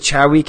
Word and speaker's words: Chowie: It's actually the Chowie cast. The V is pronounced like a W.